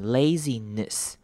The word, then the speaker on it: laziness: Laziness 这个字也是要特别注意，后面只要是 ness 结尾的字呢，这个 e 都不会念成短母音 e，而是念成短母音 i，会念成一个 i 的声音，所以我们会念成 laziness，一个 i 的声音，短而急促的短母音 i，laziness。